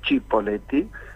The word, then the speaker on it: Cipolletti: Porque es argentinizar un apellido italiano, que si se dijera en italiano es Cipolletti, porque la C se pronuncia como CH.